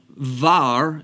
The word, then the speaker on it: bar: Now, var is how it's pronounced there in that portion. It's the Aramaic word for son. As an example, the bet vet, depending upon the context, can take either a b sound or a v sound.